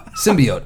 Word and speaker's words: symbiote: Symbiote. I think it's symbiote. – Symbiote. – Right? – Thank you. – S-Y-M-E.